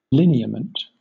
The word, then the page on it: lineament: British English